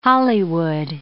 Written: Hollywood

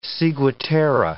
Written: ciguatera

